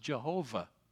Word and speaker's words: Jehovah: In the Old Testament, his name is given us Yahweh. Yahweh. Because of the influence of the German language on our English language, you have perhaps heard his name pronounced Jehovah.